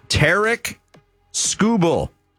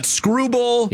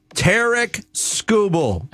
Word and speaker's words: Tarik Skubal: It's Tarik Skubal. Not Tarik. Not Tarik. Not Skubal. Not Skrubal. – Yeah. – Tarik Skubal. – I think four syllables.